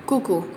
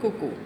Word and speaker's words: QUQU: ¿Cómo se escribe? Con Q, U, Q, U. Cucu. Cucu. – Con Q de queso.